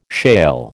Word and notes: shale: American English

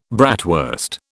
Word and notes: bratwurst: British English

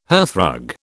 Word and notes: hearthrug: British English

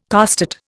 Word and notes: costate: Indian

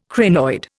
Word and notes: crinoid: Indian

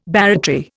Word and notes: barratry: Indian

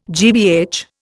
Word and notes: GBH: Indian

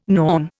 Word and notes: Norn: Indian

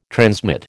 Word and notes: transmit: American English

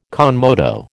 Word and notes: con moto: American English

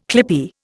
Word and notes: clippie: Indian

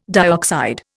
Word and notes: dioxide: Indian

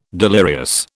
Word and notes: delirious: British English

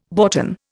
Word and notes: boughten: Indian

